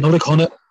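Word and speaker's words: Nollaig Shona: And I guess the only thing is to wish you and your family, is it Nollaig Seona? Is that, am I saying it right? – Nollaig Conna. Nollaig Conna.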